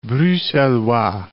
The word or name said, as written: Bruxellois